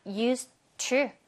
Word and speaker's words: used to: 발음을 할 때는 우리 used to 하지 말고 그냥 used to 얘는 탈락시키면 돼요.